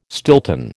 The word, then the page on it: Stilton: American English